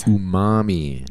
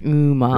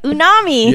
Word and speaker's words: umami: Umami. – Umami. – Right? I got it right. – Yes. Because last time you were like, unami.